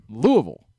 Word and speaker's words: Louisville: You can just learn how to pronounce it, Louisville.